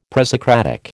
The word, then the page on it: presocratic: American English